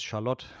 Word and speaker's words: Charlotte: Ich nehme mal an, dass es Charlotte ausgesprochen wird. – Ah, ja, äh, klar, Konditor, konditorische Meisterwerke werden immer französisch ausgesprochen.